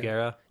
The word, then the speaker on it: Bugera: Bugera. Bugera. Yeah, is that they're pronounced.